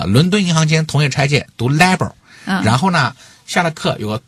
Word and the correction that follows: LIBOR: 有个学生跑过来跟我说，老师你这个发音不对，应该读 LIBOR，它是海归啊。